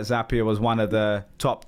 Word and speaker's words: Zapier: And you're also the founder of MakerPad, which was, you know, the leading no-code platform, got sold to Zapier. Rhymes with happier.